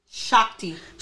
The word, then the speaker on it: Shakti: I might be saying that wrong, y'all. Shakati, Shakati energy. Shakti. Shakti energy to rise from the from my from the base of my spine and open up to the universe Wait, start over.